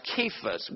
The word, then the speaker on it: Cephas: Now, in Aramaic, Cephas is generally pronounced Cephas with a K, not a C, and that was the language that they were pronouncing back then.